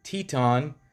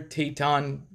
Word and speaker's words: Teton: September 19th, human remains were discovered by the FBI during a search of Spread Creek Dispersed Camping Area in Wyoming's Bridger Teton National Forest. I said it right? – Teton? The Grand Tetons. – Oh, Tetons.